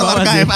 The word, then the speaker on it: archive: Ini bacanya archive kan, archive. – Archive? – Aduh. – Bener gak? – Bener gak teman-teman? – Salah. Yang bener apa dong? – Iya bener sih. – Betul, archive. Ada sih, tulisannya sih bener sih.